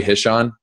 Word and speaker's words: Hishon: Joey Hishon, am I saying that right? – Yeah.